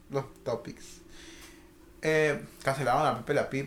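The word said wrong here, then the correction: Pepe Le Pew: primo. ¿O cómo se llama? Pepe Le Pú.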